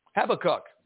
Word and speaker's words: Habakkuk: Habakkuk.